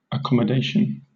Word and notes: accommodation: British English

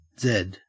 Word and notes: zed: Australian English

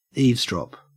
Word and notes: eavesdrop: Australian English

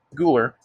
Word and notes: gular: American English

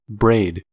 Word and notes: braid: American English

